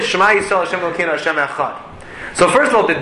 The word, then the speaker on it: Echad: We elongate the Dalet. We make the Dalet big in the way that we pronounce it. Echad. We try to lengthen the enunciation of the Dalet.